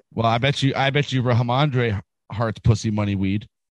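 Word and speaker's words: Rhamondre: Ramondre Stevenson ended up leaving with an ankle injury. – You mean Ramondre? – Ramondre, yeah. – Ramondre. – Sure, Ramondre. – There it is. Say it right, dude.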